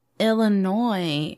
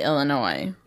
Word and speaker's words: Illinois: This happens in Harvey, Illinois. – Oh. – And I'm saying Illinois correctly, right? You do not pronounce the S at the end? – No, you pronounce the S.